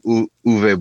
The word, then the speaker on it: Uwe: Because when I hear the name Uwe Boll, is it a V? Is it Uwe? – Well, I know it's spelled it's a W, but he's German.